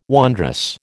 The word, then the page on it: wondrous: American English